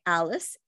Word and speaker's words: Alice: So you can follow me at makeup by Alice Ann.